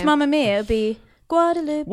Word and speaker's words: Guadeloupe: Guadeloupe. – A. Guadeloupe. – All right, so can we just nail this?